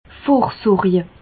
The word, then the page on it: vorsorje: Bas-Rhin; Reichshoffen